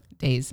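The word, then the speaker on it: Dayes: That's how you say it, Days or Dias? – Days. – Days, okay. And this is Love is the Message, because it's a banger.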